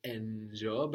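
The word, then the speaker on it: njoo: And the accent goes on the first one, njo. – Njo. – Like in I say, no? I say, A-I-S-E-E, and we have the accent on the first E. Njo, I say, on the first of the double vowel.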